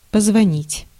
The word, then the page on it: signaler: France; [si.ɲa.le]